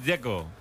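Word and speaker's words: Džeko: De Z, ¿cómo se pronuncia? – Dzeko. Bruno Dzeko. Dice que es Dzeko. – Como tirando a J, Dzeko o algo así.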